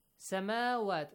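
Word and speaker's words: سَمَاوَات: Samawat. Look, on the top of waw, you have a baby alif, which is called dagger alif, and you have to pronounce it as alif.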